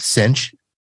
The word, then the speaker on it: Cinch: So, folks, we are talking to Mike Mosier from Cinch IT. And I should probably say this. It is spelled pretty much like it sounds. If you go to the website, Cinch, C-I-N-C-H, IT.